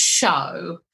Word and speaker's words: shew: And he said, now, although it's spelt shoe, S-H-E-W, the word is show.